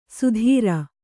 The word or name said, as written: ಸುಧೀರ